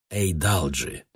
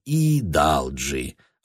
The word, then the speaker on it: Эйдалджи: «Касательно моей фамилии, она произносится Эйдалджи. Эйдалджи». – Мистер Мик произносит ее более или менее верно, но мне следует пораньше упомянуть про это вам, мистер Вачел. Полицейские, мне кажется, все время нарочито игнорировали мои поправки. Могу ли я попросить, чтобы мистер Вачел в самом начале разбирательства указал, как произносится моя фамилия? Сообщил бы суду, что она не Идалджи, а Эйдалджи.